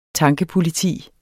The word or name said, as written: tankepoliti